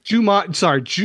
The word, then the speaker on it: Jumanji: You're not saying Jumanji, are you? – Jumanji.